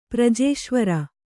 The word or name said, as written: ಪ್ರಜೇಶ್ವರ